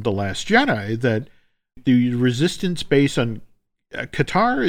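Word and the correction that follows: D'Qar: is, am I saying that right? – Dakar. – Yes.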